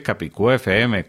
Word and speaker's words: CapicúaFM: Al buscar CapicoFM FM en cualquier plataforma, Recuerda que nuestro nombre se escribe Capicú FM con tilde en la U, todo pegado sin espacios.